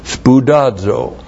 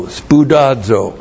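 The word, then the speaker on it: Spoudazo (σπουδάζω): Spoudazo. S-P-O-U-D-A-Z, long O. Spoudazo.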